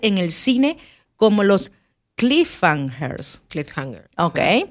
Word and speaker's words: cliffhanger: Por favor, pronúncialo bien. ¿Cómo se? – Cliffhanger. – Ok, cliffhanger. Pero su creatividad no termina en la literatura.